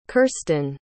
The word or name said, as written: kirstyn